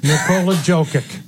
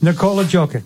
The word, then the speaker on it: Nikola Jokic: Nikola Jokic. – I don't even know that one, because I don't think you said the name right. – Probably but. – Not. It's Nikola Jokic.